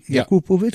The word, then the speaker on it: Jakupovic: Wie Jakupovic? Ja. Ah ja, gut, dann habe ich das ja richtig ausgesprochen. Ja.